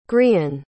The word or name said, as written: grian